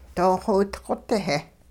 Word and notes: doo-xowidqot-heh: hupa

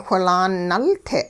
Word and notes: whilaan nulte': hupa

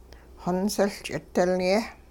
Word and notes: xonsiL ch'idilye': hupa

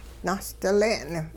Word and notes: nahsdile'n: Hupa

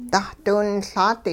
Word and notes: dahdiwinLaat: hupa

